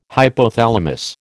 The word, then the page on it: hypothalamus: American English